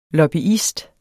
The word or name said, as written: lobbyist